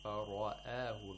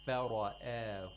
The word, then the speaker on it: فَرَآهُ: The fa should be pronounced with tarqiq, and also the alif ul-mad, just like you said, which is after the ra, has to be pronounced with tarqiq. – And I think it makes it a bit difficult. – فَرَآهُ.